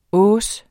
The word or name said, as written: hausse